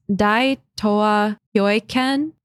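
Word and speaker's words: Dai Tōa Kyōeiken: The Japanese Empire used an ideological construct called, I'm going to butcher this, I'm so sorry, Dai Tao Kyoken. I'm going to say Kyoken. I know it's not Kyoken. I think it's Dai Toa Kyoken. – Dai Toa Kyoken. I'm sorry I butchered that.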